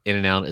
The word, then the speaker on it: In-N-Out: It's not even and, is it? It's In-N-Out. In-N-Out? – In-N-Out. In-N-Out. – In-N-Out. – In-N-Out. – In-N-Out. – In-N-Out, yes. – Here is the deal. – It is three syllables.